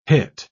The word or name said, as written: hit